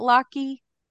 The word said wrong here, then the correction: latkes: Is that how you say it? – Latkes, the latke with the Latkes.